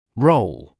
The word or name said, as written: role